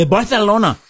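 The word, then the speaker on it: Barcelona: Yeah, BarTHelona. I'm glad you said that correctly, sir.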